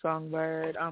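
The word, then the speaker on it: Songbyrd: You can find me on Monique Songbird.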